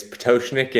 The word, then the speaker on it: Pototschnik: Yeah, my last name is Pototschnik, and you know, you're not the only one. Most people don't even try it because it's a tough one to get right.